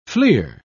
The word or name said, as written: fleer